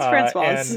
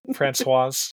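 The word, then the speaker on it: Françoise: It's Francoise. And, okay, Francoise.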